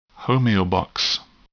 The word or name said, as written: homeobox